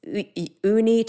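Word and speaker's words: Unni: And my name, so it's Uni Turretini.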